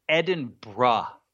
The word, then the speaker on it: Edinburgh: And I'm going to save you the embarrassment of saying it wrong like an American. It's Edinburgh. – It's not Edinburgh? – It's not Edinburgh, and they hate that. – It's spelled Edinburgh. – It is spelled Edinburgh, and they hate that too. It's Edinburgh, and that's the way they want you to say it.